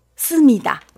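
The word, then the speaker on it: 씁니다 (思密达): 씁니다. – 씁니다. – 네, 씁니다. 씁니다. 나는 위에 살짝 이성으로 올려주시면 조금 더 정확한 발음 나옵니다.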